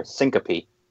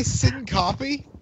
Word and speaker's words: Syncopy: It's just I think it's supposed to be a little smoother. Syncopy. – Sync my pee. – Did he say sin copy? – Yeah, I'm making copios over here. – That's what I call it. Is that not how you say it? – No, it's syncope.